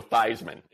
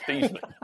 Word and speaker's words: Theismann: And literally change the pronunciation of the man's name to Joe Theismann instead of Theismann.